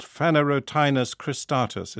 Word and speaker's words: Phanerotinus cristatus: Phanerotinus cristatus. Is that close? – Perfectly pronounced, yes.